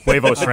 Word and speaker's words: Huevos: They stopped the Ranchos Huervos, and forgive my Spanish pronunciation. – I think it's Huevos Ranchos, Was I even close?